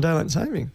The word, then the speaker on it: daylight saving: There's no S on daylight saving? – No. According to this YouTube I watched. – I've spent my entire life pronouncing that wrong.